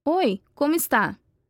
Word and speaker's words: oi, como está: You should have said, oi, como está? Repeat after him.